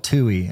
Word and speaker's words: TUI: And so the company is called TUI. Am I saying it right? – Yes. Okay. TUI is how you pronounce it.